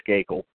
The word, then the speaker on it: Skakel: I'm not mispronouncing that either. I think it is Skakel.